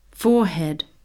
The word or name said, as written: forehead